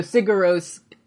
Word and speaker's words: Sigur Rós: It's like, you don't say the G, but it's like, you say it like the R's in mirror. So it's Sigur Rós. – So Sigur Rós, Joffrey, they're fucking playing the hits.